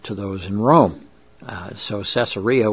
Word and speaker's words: Caesarea: Well, Caesarea, the city, was also spelled with a K, so it was actually pronounced in Greek Caesarea, because it's named after the Kaiser, Caesar, same term in Greek.